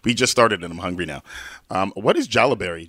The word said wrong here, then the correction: Halaberry: Yeah, it's actually called halaberry. – Oh, halaberry. I'm so sorry. – Halaberry. My name is Hayley, so we call it halaberry because it's made with jalapenos and raspberries.